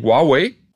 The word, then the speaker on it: Huawei: Also die Chinesen sagen immer irgendwas wie Huawei.